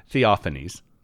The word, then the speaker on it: Theophanes: Theophanes is his name. Actually, I keep trying to put an S in the first syllable for some reason, but Theophanes.